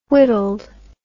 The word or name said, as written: whittled